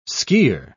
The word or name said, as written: skier